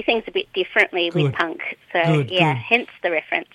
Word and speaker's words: PUNQ: Or is your pronunciation, how would you pronounce that acronym? – PUNQ. – PUNQ. There you go. – PUNQ is a Q, so Pop Up North Queensland. But PUNQ, because it's got a bit of a PUNQ edge to it.